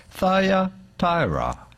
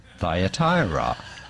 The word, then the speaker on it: Thyatira: Thyatira. Thyatira.